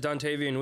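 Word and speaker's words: Dontayvion: Is that how I say his name? Dontavian? – Yeah, good job. It's Dontavian Wicks.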